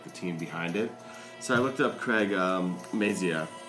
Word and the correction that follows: Mazin: Am I saying that right? – Craig Mazin. Mazin. – Oh, that's, I looked like an N.